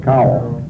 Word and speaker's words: Kowal: Are you, how do you pronounce that, Cowell? – K-O-W-A-L, Cowell.